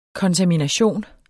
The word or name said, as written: kontamination